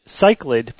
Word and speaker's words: cichlid: I had looked up the pronunciation of the fish, C-I-C-H-L-I-D, and I thought that the pronunciation guide said cichlid, but it's actually cichlid is how you pronounce it.